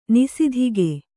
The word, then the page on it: ನಿಸಿದಿಗೆ: Kannada